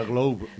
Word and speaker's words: Globe: Será Globe, ¿no? – No sé, ¿cómo se diría? Dilo, ¿no? – Globe. – Globe, vale. La E no se pronuncia.